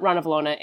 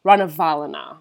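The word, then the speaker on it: Ranavalona: But actually, I also found out we've been pronouncing it wrong. It's not Rana Valona. It's Rana Valona.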